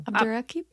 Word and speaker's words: Abdurraqib: Abdurraqib.